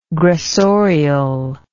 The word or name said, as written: gressorial